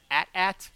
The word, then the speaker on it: AT-AT: It's pronounced casually as at or using only the letters AT-AT.